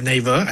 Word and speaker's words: Naver: Well, first of all, I pronounce it as Naver. I hope that's correct.